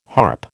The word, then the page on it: harp: American English